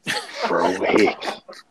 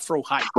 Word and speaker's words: Frohike: Frohickey. I believe that's how you pronounce it. It's not Frohike.